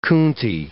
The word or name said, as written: coontie